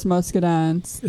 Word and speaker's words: muscadine: Yes, muscadines. – Is that how you pronounce it? – Uh-huh.